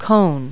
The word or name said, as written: cone